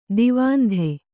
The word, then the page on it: ದಾವಂತ: dāvanta